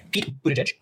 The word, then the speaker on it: Buttigieg: Then we have Pete Buttigieg.